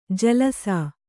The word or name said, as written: ಜಲಸ